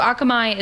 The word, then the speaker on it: Akamai: Akamai, am I saying it right? – Yeah. Akamai. Mm-hmm.